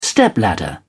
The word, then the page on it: stepladder: British English